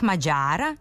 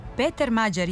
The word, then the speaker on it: Mađar: Tako bi prezime Mađar trebalo da se akcentuje u srpskom jeziku, isto kao i naziv za žitelja Mađarske. Dakle, kratkouzlazni akcenat na prvom slogu i dužina na sledećem. A onda se u promeni akcenatsko mesto pomera tamo gde je bila dužina i akcenat se menja u dugouzlazni.